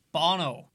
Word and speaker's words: Bono: It's pronounced Bono.